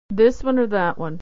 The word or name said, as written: or that one